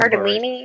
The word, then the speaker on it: Cardellini: That sounds Cardellini.